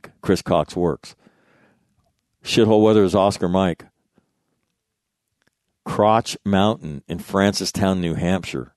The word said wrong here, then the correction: Crotched: It's pronounced crotched. Crotch Ed.